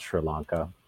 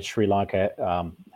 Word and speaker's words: Sri Lanka: Some people say Sri Lanka, but I think it's pronounced Sri Lanka.